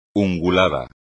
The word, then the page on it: ungulado: espagnol